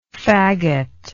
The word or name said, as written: fagoter